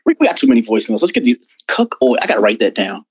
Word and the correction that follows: cuckold: What, how, how do you say it again? Cuckold. Cuckold. Cuckold cuck old. – Like, yeah, it's wait. like cuck and old at, together. Cuckold.